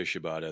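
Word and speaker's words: Katsuyori Shibata: And I'm not even going to, Katsuyori. Is that how you say his name? Shibata. – Yeah. You can just say Shibata. I think everybody knows.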